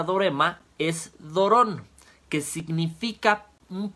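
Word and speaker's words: dorema (δώρημα): Y la palabra don viene del griego dorema, igual con acento en la O, que significa también regalo.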